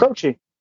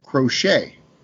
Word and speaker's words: Croce: Croce. – It's not Croce. He pronounces it crochet. – Well, that's silly. – Crochet. Not crochet like you knit.